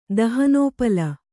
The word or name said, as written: ದಹನೋಪಲ